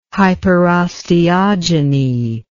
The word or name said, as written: hyperosteogeny